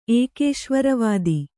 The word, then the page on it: ಏಕೇಶ್ವರವಾದಿ: ēkēśvaravādi